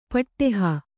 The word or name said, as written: ಪುಡೆಹ